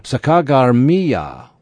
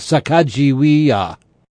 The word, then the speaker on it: Sacagawea: The captains named this river Sacagawea, or Birdwoman's River, after their Shoshone interpreter, and thus starts the controversy of pronouncing her name. In his edition of the journals, Elliot Cowes reads the spelling as Sacagawea.